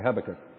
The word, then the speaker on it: Habakkuk: And yes, that's how you pronounce it, by the way, Habakkuk.